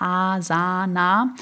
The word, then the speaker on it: Asana: Und was es dann noch gibt, ich hoffe, ich spreche es jetzt richtig aus, Asana wird es geschrieben, Asana oder Asana ausgesprochen, also einfach Asana.